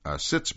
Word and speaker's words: sitz: Stitz bath, sitz bath, I should say, that's spelled S-I-T-Z, vapor cabinet, and needle shower.